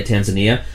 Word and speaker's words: Tanzania: Tanzania? – Yeah, Tanzania.